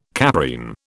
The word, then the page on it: caprine: British English